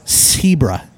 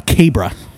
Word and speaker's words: Cebra: I think it's Cebra, spelled with a C, C-E-B-R-A. Cebra, it's got a little asterisk over the C, so that means it's pronounced like an S, right? It's not Cabra.